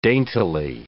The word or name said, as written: daintily